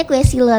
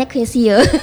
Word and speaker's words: Quesillo: Aku tuh sempat bacanya Quesillo, tapi salah ternyata pelafalannya Quesillo.